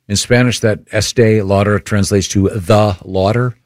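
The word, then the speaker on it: Estée Lauder: Is it pronounced Estee Lauder? – Estee Lauder, yes.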